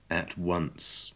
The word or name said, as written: at once